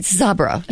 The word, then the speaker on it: Sabra: How do you say it? – Sabra. Yeah.